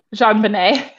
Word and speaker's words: JonBenet: I don't know how to say her name. – JonBenet. – JonBenet. Okay.